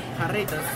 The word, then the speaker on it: Jarritos: And am I pronouncing it right? Jarritos? – Jarritos, sí.